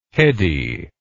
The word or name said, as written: hedy